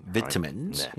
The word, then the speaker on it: vitamins: You see the British pronunciation close to the Korean one, vitamin. – Vitamins.